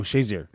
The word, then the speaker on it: Shazier: Al Shear? Is that how you say it? – Yeah. Oh, no. Shazier.